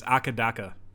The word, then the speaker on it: AC/DC: The AC/DC pronunciation in Australia is Akadaka.